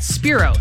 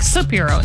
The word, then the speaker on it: Spiro: Spiro. Sienna Spiro. I'm trying for whatever reason to say her name wrong. Like I try to add an extra I in there, like Spiro. And it's like, no.